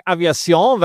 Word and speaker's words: Aviation: Eine Dassault Aviation. Ich hoffe, ich habe die richtig ausgesprochen. – Ich würde sagen Aviation, weil es Franzosen sind und die hast du dann rausgenommen, ja? – Ja, weil sie einen Umsatzrückgang auf 3-Jahres-Sicht haben.